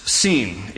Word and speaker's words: Sin: If there's a dot over the left branch, it is pronounced seam. If it is over the right branch, it is pronounced shin.